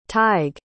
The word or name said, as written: tyg